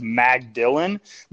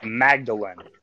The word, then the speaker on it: Magdalen: it looks like it says Magdalen, but it's supposed to be pronounced Magdalen. – Hmm. – Okay. So just run the words together. – Yes, but like Mary Magdalene.